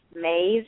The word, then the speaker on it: Maeve: The card I drew is, I think her name is pronounced Maeve. It's M-A-E-V-E, and it's called.